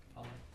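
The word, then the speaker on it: Kephale (κεφαλή): Kephale? – Kephale, yep.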